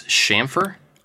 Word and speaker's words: chamfer: Did you guys know that it's not ch? It's a sh sound. It's chamfer.